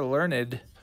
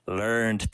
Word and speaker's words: learned: You are learned. – Learned, Pepe. It's pronounced learned.